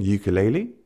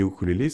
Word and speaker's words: ukulélé: Parce que ukulélé, ça vient de la prononciation américaine, ukulélé. Et c'est vrai que le rayonnement de notre instrument, il passe par des artistes dans les années 20-30 qui avaient des ukulélés, ça a été popularisé, des petites chansons, etc.